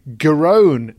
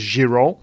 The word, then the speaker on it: Giron: Did watch Evans, and he won through today against Marcus Garon, it turns out you pronounce his name, which I didn't think was how it should be pronounced at all. I thought it was Giron. And then we played the sound of it on the ATP website, and that was how he pronounced his own name.